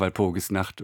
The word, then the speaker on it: Walpurgisnacht: Groß N und eine 8. Was hat es damit auf sich? – Ja, das war eine Idee von unserer großartigen Grafikerin Nicole Winterbauer. Es spricht sich aber einfach Walpurgis Nacht und ist, glaube ich, ein Eyecatcher.